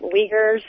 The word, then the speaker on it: Uyghurs: Uyghurs, is that how you pronounce it? Uyghurs? – Yep. U-I-G-H-U-R.